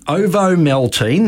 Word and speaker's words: Ovomaltine: A malted milk drink called Ovomaltine, that's how you pronounce it, was released in Switzerland.